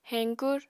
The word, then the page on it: heñkur: Apatani